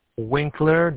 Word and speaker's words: Winkler: N as Nancy, A, F as Father, T as Tom, A-L-I. – And how do you spell your last name? – Winkler, W-I-N-K-L-E-R.